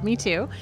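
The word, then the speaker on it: mitú: Is that how it's pronounced? – Yes, it is called MeToo. – But it's spelled M-I-T-U, so in Spanish, MeToo.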